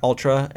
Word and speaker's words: Altra: Ultra, and it's spelled A-L-T-R-A. – Oh, yeah. – T-R-A.